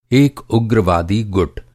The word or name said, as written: गुट